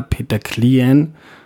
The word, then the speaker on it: Peter Klien: wenn mir jetzt da Peter Klien, ist jetzt richtig ausgesprochen? Ja.